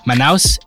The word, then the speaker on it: Manaus: Manaus.